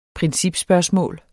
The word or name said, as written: principspørgsmål